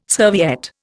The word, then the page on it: serviette: Indian